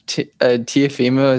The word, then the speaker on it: Teofimo: Dude, Teofimo, is that how you say his name? – Yeah.